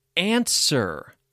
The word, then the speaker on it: answer: Number one, answer. A-N-S-W-E-R. Answer has a silent W. What is the answer to the question? Sword. S-W-O-R-D.